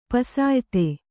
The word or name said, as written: ಪುಸಿಯೋತ